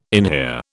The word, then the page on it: inhere: British English